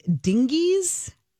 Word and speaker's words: Dinges: Where is it? Waffles and Dingies. Am I saying it right? D-I-N-G-E-S. Sorry.